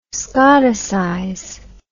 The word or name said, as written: scotticize